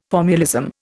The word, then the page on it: formulism: Indian